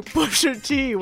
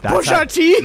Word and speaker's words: Pusha T: Pusha T. – That's how you say it. You don't say it. You got to come correct. No, you don't say Pusha T like I'm having tea.